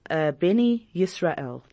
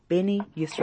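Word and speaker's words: Bnei Yisrael: He'll tell you, I am from Beni Yisrael. That is how they say it. Beni Yisrael.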